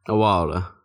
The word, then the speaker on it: Awalda: How do you pronounce that again? – Awalda. – Awalda? – Yeah. – Like a while ago? – Sure.